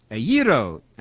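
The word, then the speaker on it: Aiero (ἐγείρω): Aiero, as modern Greeks would pronounce it, means to raise from the dead.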